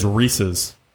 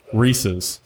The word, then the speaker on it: Reese's: My wife would contest that it is Reese's. Reese's. Like the name Reese? – Reese's? – Reese's. – Say it again. Reese's. – No, it's not Reese's. – Reese's is how I say it. – You're putting an extra E on it.